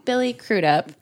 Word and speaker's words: Crudup: Billy Crudup.